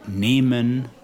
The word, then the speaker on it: nehmen: So before L as in fehlen, and then before M as in nehmen, that's N-E-H-M-E-N, which means to take.